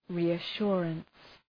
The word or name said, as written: reassurance